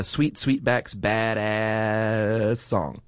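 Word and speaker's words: Sweet Sweetback's Baadasssss Song: You got to say it right, too. – Melvin Van Peebles' film, Sweet Sweetback's Badass Song. – Nice. Yep. That was well done.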